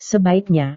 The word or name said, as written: sebaiknya